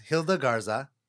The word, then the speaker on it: Gilda Garza: But my guest today is Gilda Garza.